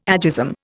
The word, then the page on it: agism: Indian